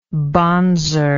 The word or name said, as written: bonzer